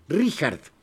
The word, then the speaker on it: Richard: 1864 nació Richard Strauss, cuando hay CH en alemán se pronuncia J. Richard. Bueno, este no tiene que ver con los de los valses, esos eran los de Viena.